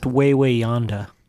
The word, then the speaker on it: Wawayanda: It's pronounced Wawayanda. – Is it? – Yeah. I wanted it to be pronounced Wawayanda, but someone corrected me.